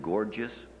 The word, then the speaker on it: Gorgias: The Latin American liberation theologian Segundo, in his Grace and the Human Condition, cites a parable by Rodeau entitled Farewell, O Gorgias. Incidentally, if anybody can help me with that pronunciation, he was a 5th century Greek rhetorician. Can anybody help me with the pronunciation? Is it Gorgias? – Yes.